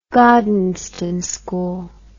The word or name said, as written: Gordonstoun School